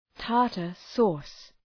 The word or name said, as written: tartar sauce